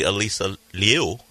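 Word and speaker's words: Alysa Liu: Since the name sounded like maybe Elisa Liu, which is actually how you say her name, is related to Gilbert as well.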